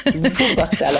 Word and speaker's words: Wuppertal: Et Youpertal encore pire. – Wuppertal avec W, avec V.